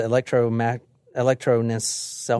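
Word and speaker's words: electroencephalogram: They hooked him up to an alcohol electrosynlophagram. Basically, that measures brain waves. I can't pronounce it. Electronencephalogram.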